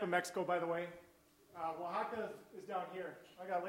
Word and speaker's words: Oaxaca: Difficult for a lot of people to say, but I was telling people, if you can say Wapaca, you can say Oaxaca.